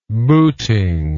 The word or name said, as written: booting